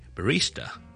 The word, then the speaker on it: barista: 而咖啡师咧，我哋叫做咧，呢个字来自意大利文啦，当然系 barista barista barista barista 系 b a r i s t a b a r i s t a，重音喺第二个 syllable。